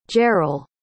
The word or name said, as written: jarel